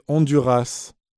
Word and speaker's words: Honduras: No, se escribe con U, G-U-E, y con acento en la E. – 3. – ¿Cómo se escribe Honduras? ¿Con H o sin H? – Se escribe con H, pero no se pronuncia.